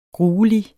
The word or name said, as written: gruelig